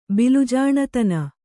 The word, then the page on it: ಬಿಲುಜಾಣತನ: kannada